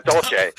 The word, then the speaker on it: Dolce: The Dolce & Gabbana tunnels. – Wait, I said that wrong. Not Dolce & Gabbana. I'm sorry. Dolce.